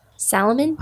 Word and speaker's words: salmon: But you got to pronounce the L. – Salmon? – Yeah. – Nobody pronounces the L in salmon.